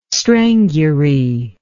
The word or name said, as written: strangury